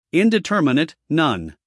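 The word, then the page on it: indeterminate: uk /ˌɪndɪˈtɜːmɪnət/; en-us /ˌɪndɪˈtɜːrmɪnət/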